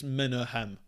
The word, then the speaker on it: Menahem: I was trying to figure out how to pronounce Menahem there, and I think it's Menahem.